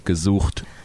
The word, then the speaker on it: gesucht: Gesucht. – Yeah, like kazoo, and then you gotta you gotta hawk the loogie, like.